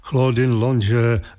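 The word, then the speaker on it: Claudine Longet: května Claudine Longe, vysloveno francouzsky, hlavně v 60.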